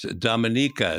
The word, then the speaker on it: Dominica: It's Dominica is the correct pronunciation.